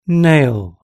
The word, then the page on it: nail: British English